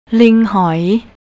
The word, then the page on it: linghoi: thai